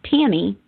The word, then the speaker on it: Tami: is Tammy, and it's spelled T-A-M-I.